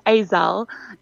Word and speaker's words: Yzelle: So it's Cezanne, and my surname is Yzelle. It's a Yzelle.